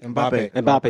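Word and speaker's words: Mbappé: Mbappe? Is that how you say it? – Mbappe. – Excuse me.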